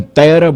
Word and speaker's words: Terra: Eu agora falei a pronúncia correta, né? Eu comecei com Terra, botando um acento onde não tinha.